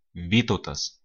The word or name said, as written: Vytautas